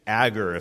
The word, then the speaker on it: Agur: Proverbs 30 is written by a man named Agur, if I'm saying that right. You'll see in verse 1, it's spelled A-G-U-R.